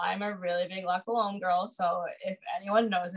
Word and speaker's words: La Colombe: It's like the La Colombe. Is that how you say it? – Oh my gosh. Yes. The La Colombe.